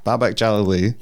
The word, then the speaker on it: Jalali: Jalali like Galilee. – Yeah, so the emphasis is always on the last syllable. – Okay. Jalali. – Jalali. – Babak Jalali.